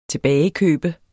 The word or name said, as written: tilbagekøb